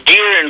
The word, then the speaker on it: Gear: Let's go to, now, I hope I pronounced the name correctly. Is it Jerry in Florida, or do I mispronounce your name? – Hello. – Yeah. – This is Gear in Florida. – Gear, okay, just the way it reads. – G-E-R-E.